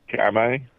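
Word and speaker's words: Kamay: It says K-A-M-A-Y. I'll have to... Can you... Is that right or wrong? – That's right. – So you pronounce it Kame? Kame? – Kame.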